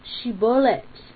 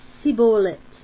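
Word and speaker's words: shibolet (שבולת): He thus indicated that Ephraim's descendants would be caught like fishes, and it's because they couldn't say shibolet, they would say sibolet in Judges 12:6. So they were caught by their mispronunciation of this word.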